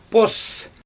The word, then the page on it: poos: Xyzyl